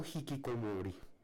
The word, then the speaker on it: hikikomori: Hikikomori, hay una duda en pronunciación, si se dice Hikikomori o Hikikomori, yo he escuchado pronunciar en japonés y lo pronuncia como Hikikomori.